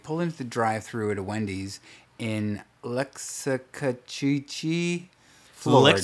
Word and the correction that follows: Loxahatchee: Oh, I think that's Loxahatchee. – Okay. – Loxahatchee, because I think that's how that's pronounced.